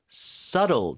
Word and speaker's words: subtle: Pick up on, subtle. 자, B는 묵음이죠? 다시 한번 읽어주세요. – Subtle.